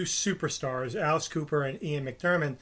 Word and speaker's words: McDiarmid: And then the Emperor, Ian McDiarmid.